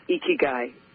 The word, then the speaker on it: Ikigai: It's I-K-I-G-A-I. So it's pronounced Ikigai.